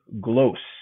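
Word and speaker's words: Głos: Canadian Polish language newspaper called Glos. Rhymes with post, but with no T and a G and lots of anti-Semitism, as it turns out.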